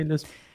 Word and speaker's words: Lisp: Wird, wird es Lisp ausgesprochen oder LISP? Lisp. Lisp. – Nee, nee, Lisp.